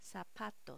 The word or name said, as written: zapatos